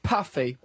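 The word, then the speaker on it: Puffi: I was very amused when living in Italy to discover the Smurfs were translated as puffy, pronounced as in poof.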